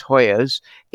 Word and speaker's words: Hoyos: Our guest today is Jody, and Jody may help me pronounce her last name, but I think it's Hoyos, H-O-Y-O-S.